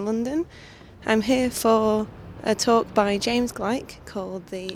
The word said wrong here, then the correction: Gleick: Actually, it's pronounced James Glick.